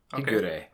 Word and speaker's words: Higure: Higure. – Okay. – Higure. Higure. Because it's always consonant and then vowel. And each of those is pronounced as a syllable.